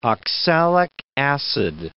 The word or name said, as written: oxalic acid